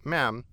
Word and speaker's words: Ma'am: When conversing with Elizabeth, the correct etiquette was to address her initially as Your Majesty and thereafter as Ma'am, pronounced Ma'am with a short A, as in jam.